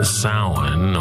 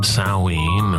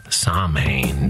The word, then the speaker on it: Samhain: It's called Samhain or Samhain or Samhain, depends on your particular Celtic pronunciation, your particular tribe. Americans sometimes say Samhain due to the way it's spelled. And due to the fact that Glenn Danzig once had a band of the same name, and because horror punk record covers rarely come with Gaelic pronunciation guides.